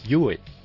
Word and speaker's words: Huawei: Esto lo habíamos mirado cuando se pronunciaba. Y es Huawei.